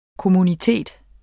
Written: kommunitet

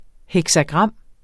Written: heksagram

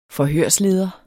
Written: forhørsleder

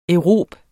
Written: aerobe

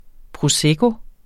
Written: prosecco

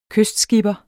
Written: kystskipper

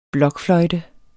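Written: blokfløjte

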